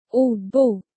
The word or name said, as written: ordbok